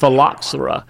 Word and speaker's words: phylloxera: And let me say that word one more time so you'll know how to pronounce it for people that ask about it. It's called phylloxera.